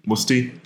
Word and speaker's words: Mutzi: So, Mutzi, is that how you do it? I can't pronounce foreign names very well. – No, you're well. – Doing it right. – Mutzi.